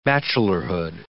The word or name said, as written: bachelorhood